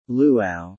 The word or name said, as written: Luau